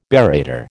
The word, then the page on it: barrator: American English